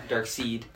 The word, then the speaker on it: Darkseid: Obviously, Darkseid spelled dark, and then S-E-I-E. – Seed, yeah. Dark seed. – But then he says Darkseid, so then you kind of know how to pronounce it.